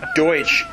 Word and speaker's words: Deutsch: Well, that was just Americans mispronouncing Pennsylvania Deutsch. Because Deutsch is the German word for German.